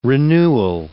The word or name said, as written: renewal